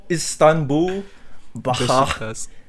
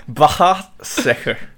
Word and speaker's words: Başakşehir: All right, let me just educate you and all the listeners. It's Istanbul Başakşehir. Başakşehir.